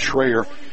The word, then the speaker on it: Schroer: Caleb Schreier, spelled S-C-H-R-O-E-R, pronounced Schreier, had a magnificent touchdown reception on a fade pattern in the corner of the end zone from 19 yards out.